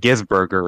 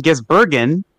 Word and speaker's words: Gisbergen: Yeah, it's not Burger, Zach. It's not. Last time we said his name on here, you said Gisberger or something. Shane Van Gisbergen,